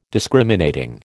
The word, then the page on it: Discriminating: American English